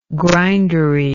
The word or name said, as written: grindery